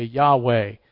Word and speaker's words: Yahweh: All that to say, we don't know exactly how to pronounce it, but generally you will hear scholars today say Yahweh.